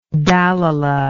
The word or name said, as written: Dalila